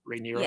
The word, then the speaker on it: Rhaenyra: Um, is that how you pronounce it, Rhaenyra? – Yes. Yes. yes.